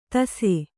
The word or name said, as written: ತಸೆ